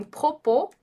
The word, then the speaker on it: propos: Esse som de O também pode aparecer antes de uma letra que não é pronunciada, como por exemplo em propos e como por exemplo em caô. Propos e caô. Então, você já sabe que o S a gente não pronuncia em francês, né? Quando ele está no final ali de palavra. Então, o O que vem antes dessa letra que não é pronunciada, ele vai ter som de O, tá?